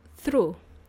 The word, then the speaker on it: through: Let's look at this word through. Through, which is spelt with 7 letters. T-H-R-O-U-G-H. Yes. We don't even pronounce the G.